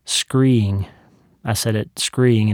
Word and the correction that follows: scrying: and it's actually scrying, so I had to learn how to say that word too.